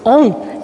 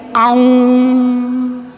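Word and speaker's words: Aum: O A-um, que a gente diz assim, on, a gente pronuncia A-um, A-um.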